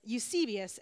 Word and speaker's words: Eusebius: So there's a guy by the name of Eusebius, which, to be honest, I Googled how to pronounce his name. So Eusebius, and then I have it written phonetically.